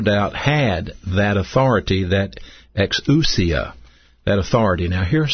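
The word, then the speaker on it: exousia (ἐξουσία): This word power is pronounced exousia. Exousia.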